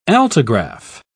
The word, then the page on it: altigraph: British English